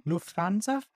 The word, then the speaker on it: Lufthansa: Lufthansa, yes. Is that how you say the airline? – Lufthansa.